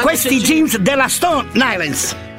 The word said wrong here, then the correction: Stone Island: Stone Island.